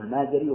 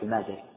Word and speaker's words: المازري: المالكية عندهم كتاب هو كتاب المذهب لا قبله ولا بعده، ولذلك كل القواعد المستخرجة يرجعون لهذا الكتاب، وهو كتاب شرح التلقين المازري، بعضهم ينطقه المازري وبعضهم ينطقه النازري، والأشهر بالفتح المازري.